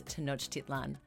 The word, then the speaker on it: Tenochtitlan: So to make that correction, the name of the city is Tenochtitlan.